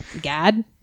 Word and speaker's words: Gad: The prophet, I don't even know how to say his name, Gad, G-A-D, Gad, he's like from Boston.